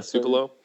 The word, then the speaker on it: Tupelo: Is that how you say it? Yeah, Tupelo.